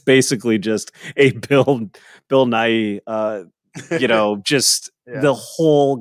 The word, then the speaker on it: Nighy: Is it pronounced Bill Nighy or Nye? I always Nighy. – Nighy? – Nighy. – I thought it was Nighy. I can't say I've like written him a letter and asked him. – Nye. I remember the end is Nye in Superman 2. There was a sign. That's where I first heard the word Nye. – Was it spelled the same way? – N-I-G-H only. And Nye. – Right. – So Nye Hi.